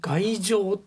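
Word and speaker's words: 崖上: 外情ってね、あんま聞いたことないですよね、うん、あの、うんうん、崖の上と書いて外情って読ませるんです。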